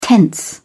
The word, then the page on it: tense: British English